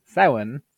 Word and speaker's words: Samhain: So uh, for those of you who didn't really feel like Logan's Samhain, it is pronounced Samhain, survey last week really no scratched your the itch for your 31st of October tingly feelings.